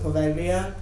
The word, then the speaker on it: Poveglia: If I were to not bastardize the pronunciation with my English speaking self, it would be something more along the lines of Poveglia.